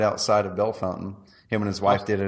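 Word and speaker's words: Bellefontaine: He goes, you're talking about Belle Fontaine or whatever it was. Yeah. He said, you're talking about Belle Fountain, right? I'm like, I don't think so, dude. That's not how it's spelled. And he goes, no, it's Belle Fountain.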